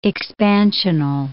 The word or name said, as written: expansional